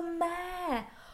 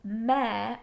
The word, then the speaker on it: Mare: And it's not the it's not the mayor. Oh, you are driving me mad with this. It's Mare, and that's her name, and it's not M-A-Y-O-R. Is it M-E-R-E? Is that how you spell it? – It's T-H-E-M-A-R-E.